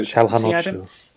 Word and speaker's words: Çalhanoğlu: Tufan, and obviously the man that most people are very excited about at the moment, which is, how do you say him? – Çalhanoğlu. – There you go. – Çalhanoğlu.